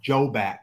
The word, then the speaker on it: Jobak: It's pronounced Jobak, J-O-B-A-K, it sounds like. – Okay, great. – It's a hard J sound.